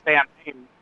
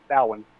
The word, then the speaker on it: Samhain: But because I like folklore and ancient history and all that stuff, I knew that the original pronunciation, though, is Samhain, you know, for even though it looks like Samhain, it's Samhain. You know, you get a W and an I sound there from somewhere.